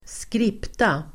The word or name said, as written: skripta